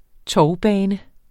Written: tovbane

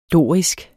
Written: DORISK

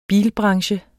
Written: bilbranche

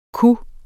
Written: kunne

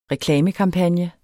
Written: reklamekampagne

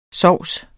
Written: sovse

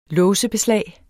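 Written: låsebeslag

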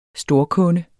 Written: storkunde

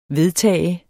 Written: vedtage

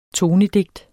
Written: tonedigt

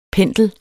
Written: Pendler

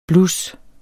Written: blus

